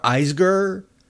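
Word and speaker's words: Isgur: They just hired, not too long ago, Sarah Eisger. I guess that's the pronunciation, although it rhymes with Eisner.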